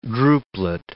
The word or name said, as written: drupelet